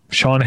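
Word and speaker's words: Sian: I'm going to guess it's pronounced like Sean, but it's S-I-A-N. Sean Heder.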